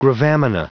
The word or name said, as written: gravamina